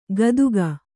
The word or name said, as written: ಘಾತುಕ